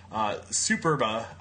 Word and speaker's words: Superba: Paul is the chef, proprietor, restaurateur, whatever you want to call it, behind Pitfire Pizza, Superba. I hope I'm saying that right. I'm an East Coast guy. – That's right.